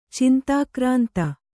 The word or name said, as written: ಚಿಂತಾಕ್ರಾಂತೆ